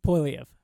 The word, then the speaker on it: Poilievre: Poilievre. – Pierre Poilievre. – Yep. – There's an R in there, and it's apparently silent. – It's a silent R.